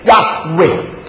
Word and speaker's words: Yahweh: 耶和华对我主说，耶和华 JYHWH，在希伯来文应当问念成 Yahweh， Yahweh 翻译成中文的时候 Jehovah， Jehovah。